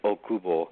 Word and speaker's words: Okubo: And yes, my name is Jin Okubo.